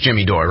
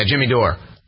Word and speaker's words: Jimmy Dore: I think it's Jimmy Dore, right? Yeah, Jimmy Dore.